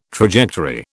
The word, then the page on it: trajectory: British English